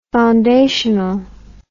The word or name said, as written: foundationally